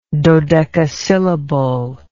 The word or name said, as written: dodecasyllable